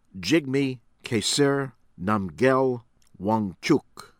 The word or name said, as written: Jigme Khesar Namgyel Wangchuck